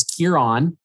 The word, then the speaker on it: Kieran Hinds: And he phonetically spelled it out, and it's Kieran, and then Hines rhymes with Bynes.